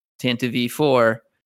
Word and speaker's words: Tantive IV: But Ron, our buddy Ron, said that he always thought it was pronounced Tantive IV. And everyone just mercilessly destroyed. Everyone has made fun of him so hard. So he was thrilled when Leland confirmed that was the correct pronunciation.